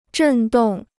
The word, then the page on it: 振动: zhèn dòng